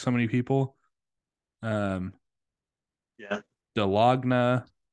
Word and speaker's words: Dahlonega: I would say 95% of people do not know how to pronounce this ride. It is the Dahlonega Mine Train, the 1967 Mine Train, which it was the second mine train created.